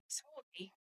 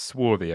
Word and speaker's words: swarthy: Swarthy, dark complexion. – Dark complexioned. – Oh, okay. – And it's swarthy, apparently, not swarthy. – Oh, swarthy, okay. – I pronounced that one wrong.